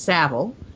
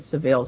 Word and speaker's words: Savile: I found out his name is pronounced Savile. I was pronouncing it Savile, so now it's going to be Jimmy Savile.